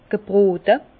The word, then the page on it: gebrote: Alsatian; Bas-Rhin; Herrlisheim